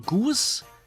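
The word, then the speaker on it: GOOS: Laut Go-Erfinder spricht man die Environment-Variablen Goose und Gorge aus, ja.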